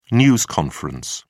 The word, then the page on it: news conference: British English